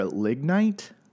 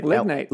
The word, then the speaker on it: lignite: It's made through the gasification of coal or lignite. Am I saying that right? – Lignite. – Lignite.